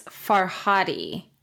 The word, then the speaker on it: Farhadi: I think I'm pronouncing, I'm going to say Fardi, F-A-R-D-I, Faradi. – I believe it's Farhadi. – It is Oscar Farhadi.